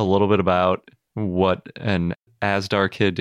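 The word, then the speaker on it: Azhdarchid: So since you have this new pterosaur, it's an Asdarchid, right? Is that how you say it? – Yep. Some people emphasize the zh a bit, so more Asdarchid, but it's fine.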